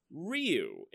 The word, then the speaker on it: Ryu: Speaking of Andy, he also mentioned that apparently everyone in the universe has been saying the Street Fighter character Ryu's name incorrectly. According to him, it's pronounced Ryu, and it's said almost like it's one syllable, like Rue.